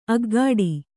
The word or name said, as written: ಅಗ್ಗಾಡಿ